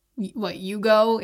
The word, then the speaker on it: jugo: Or jugo juice. It's actually pronounced yugo in Spanish, and it's juice.